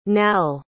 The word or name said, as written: nael